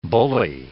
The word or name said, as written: bullae